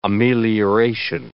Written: amelioration

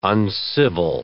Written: uncivil

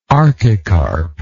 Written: archicarp

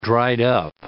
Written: dried-up

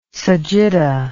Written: sagitta

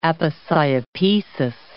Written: aposiopesis

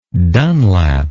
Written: dunlap

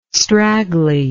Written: straggly

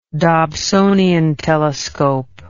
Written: dobsonian telescope

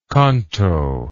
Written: conto

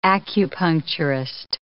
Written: acupuncturist

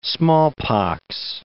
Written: smallpox

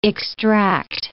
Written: extract

